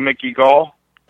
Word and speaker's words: Mickey Gall: And give credit to Mickey Gall. So, did I get his name right? – Yep. – Mickey Gall.